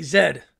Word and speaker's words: Z: Brits say, when they say Z, they say Zed.